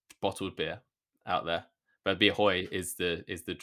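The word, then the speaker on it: Bia Hơi: Bia Hoi. – Bia Hoi.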